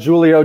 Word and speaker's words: Julio: What about Julio Jones? I know you're a big fan of him, and now he doesn't have the stink of the Falcons on him. – Big Julio, hard J guy.